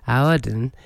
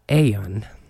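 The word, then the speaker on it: Aodhán: So I've been saying Audhan. It's actually an Irish Gaelic name, and you pronounce it something like Aeon.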